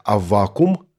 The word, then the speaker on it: Аввакум: Некоторые говорят Аввакум, другие говорят «Аввакум» с ударением на второй слог. Поэтому скажу пару слов об ударении. Вообще, строго говоря, произношение «Аввакум» более правильное. Кстати, если хотите уж совсем правильно, то вспомните, что в древнееврейском языке имя этого пророка оканчивалось на «к», а не на «м», то есть «Хабакук».